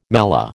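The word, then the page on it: mêlée: American English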